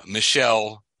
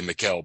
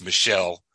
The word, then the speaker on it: Michel: French philosopher and essayist, Michel is the way they pronounce it. I would say Michel, but it's Michel, M-I-C-H-E-L, Montaigne wrote, and he wrote, "My life has been full of terrible misfortunes, most of which have never happened."